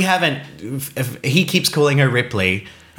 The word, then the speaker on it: Ripley: I said, oh, you mean Ripley? And he said, oh, is that how you say it? Ripley, because in French it's Replay.